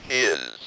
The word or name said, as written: his